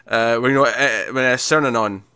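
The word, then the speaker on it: Cernunnos: You know, Cernanon. Did I say that right? – Yeah.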